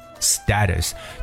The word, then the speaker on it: status: status，其实这个单词有两种发音，英国人会读成 status，就是我们所说这样一个地位，对不对？比如说社会地位就叫做 social status 的，但是在美语当中呢，这个词读 status。